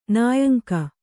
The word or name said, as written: ನಾಯಂಕ